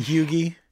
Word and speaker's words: Huger: Vandross Street is spelled V-A-N-D-E-R. H-O-R-S-T, Vanderhorst. But it's Vandross. – And Hugie? – A Hugie Street. – Hugie. – Which is H-U-G-E-R. – That's where I'd live. – Legree. – I'd live on Hugie Street. – Hugie Street. And then Legree Street is like Legare, like L-E-G-A-R-E, Legree.